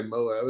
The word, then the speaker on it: MOAB: You can say Moab. It's an acronym.